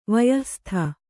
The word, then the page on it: ವಯಃಸ್ಥ: kannada